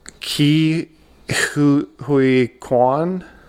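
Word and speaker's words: Ke Huy Quan: Ki-Hui Kwan.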